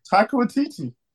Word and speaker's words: Taika Waititi: No, you just not pronounced it right at all. – Ain't that what my man's name is? – You said Taika Waititi.